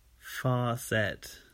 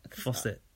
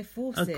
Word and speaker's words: faucet: Also, I've always heard Americans say, far set. – What? How's she saying it? How's that written? – Well, it's spelled F-A-H-C-E-T. Far set. – Far set. Is that how you said it? – Faucet. – I say faucet. – Kwame said he says faucet, like I do.